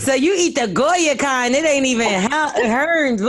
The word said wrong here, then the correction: Heinz: What is it? How you say it? Heinz. I said Hearns. Like I'm from fucking Baltimore. – It's not Hearns.